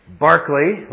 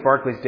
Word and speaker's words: Berkeley: And it is pronounced Berkeley, like Sir Charles, not Berkeley, like the college, which mispronounces his name, which was named after him. But that's another story. His dates, Berkeley's dates, are 1685 to 1753.